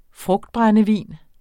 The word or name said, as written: frugtbrændevin